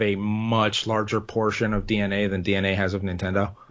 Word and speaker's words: DeNA: I might start with if you know how to pronounce this, DNA, is DENA, this company, yeah, DNA.